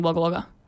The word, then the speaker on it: Wagga Wagga: They're saying it's pronounced Wagga Wagga. – I lived there for 3 years. It's definitely Wagga Wagga.